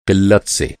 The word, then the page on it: क़िल्लत: قِلّت